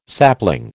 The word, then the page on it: sapling: American English